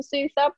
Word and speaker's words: Suthar: I've recently changed everything to KSuthar rather than Kaplan Suthar, because I'm finding that a lot of people are struggling with saying my name, pronouncing my name.